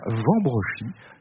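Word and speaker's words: Wambrechies: Des choses magnifiques dans ce musée de la poupée et du jouet ancien, dans ce château de Robertsart de Vambrechy, dont le nom s'écrit avec un W au début, mais on dit bien Vambrechy.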